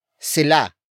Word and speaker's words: sila: The next word is sila. Sila. Sila. Sila is spelled as S-I-L-A.